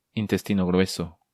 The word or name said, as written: Intestino Grueso